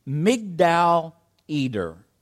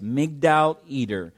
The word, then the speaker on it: Migdal Eder: Now, if you're to read this in the Hebrew is pronounced Migdal Eder. It's a town, it's a place, a specific place, Migdal Eder, and it's in the north section of Bethlehem.